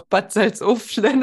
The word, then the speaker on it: Bad Salzuflen: Also wenn ihr mehr darüber erfahren wollt, ich packe euch gerne den Link zu Ina Salinas Live-Seminar in Bad Salzuflen. Nee, wie wird das ausgesprochen? – Doch, Bad Salzuflen.